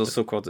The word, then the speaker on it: Sukkot: Genau, also Sukkot ist richtig ausgesprochen.